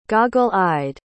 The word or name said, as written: goggle-eyed